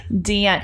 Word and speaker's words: Diane: But Diane, it's spelled Diane, but I'm trying to be French.